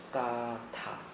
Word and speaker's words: gatha: 这个伽陀呢，其实应该念成伽陀，因为梵文是 gatha， G A T H A，叫 gatha，啊 gatha， gatha 呢是古印度的一种文体，一种文体，它是梵文，它的梵文在我们中文里面没有对应的名词，这个叫做音译，这个叫音译。